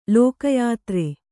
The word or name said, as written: ಲೋಕಯಾತ್ರೆ